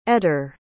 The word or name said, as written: etter